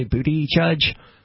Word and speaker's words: Buttigieg: And what's so it would be like the word Buttigieg. – So the gay man has a last name that's basically booty judge? – Something like that, yeah.